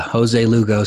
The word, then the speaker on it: Jose Lugo: Yeah, Jose Lugo sounded pretty good to me.